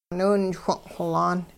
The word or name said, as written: niwinchwing' xolaan